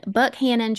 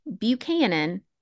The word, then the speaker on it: Buchanan: Now, off the rip, I need to clarify with y'all that despite being spelled like it would be pronounced Buchanan, it is in fact Buckhannon. And Rob, if you hear me slip and say Buchanan, please call me out so I don't sound dumb. It is Buckhannon.